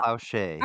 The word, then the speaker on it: Plauché: Plowshay.